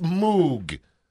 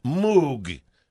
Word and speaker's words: Moog: It's Moog, like the sound a cow makes, Moog.